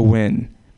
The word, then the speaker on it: Nguyen: My name is Velasco Nguyen. That's Nguyen. Like everyone has a Vietnamese friend with the last name Smith. Look, it's a monosyllabic language. It's really easy. You know, one syllable per word, win.